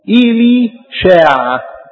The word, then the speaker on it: إليشع: احنا بنقول إليشع ده النطق العربي، انما في العبري لما اليهودي ينادوا إليشع اسمه إيلي شاع. اسمه في العبري كده عند اليهود إيلي شاع.